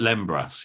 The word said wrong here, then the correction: lembas: yeah? – Lembas. – Lembas, thank you.